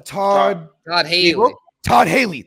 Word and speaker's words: Todd Haley: He was with Hugh Jackson and Todd Campbell. Am I saying that right? He was former OC from the Steelers. – Mm-hmm. – Todd Haley.